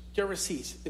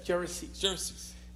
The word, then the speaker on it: Gerasenes: From I think it's pronounced Gerasenes in English. Gerasenes.